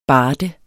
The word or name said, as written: BARDERNES